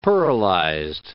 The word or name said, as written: pearlized